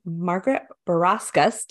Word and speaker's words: Margaret Barawskas: So I have Margaret Barawskas.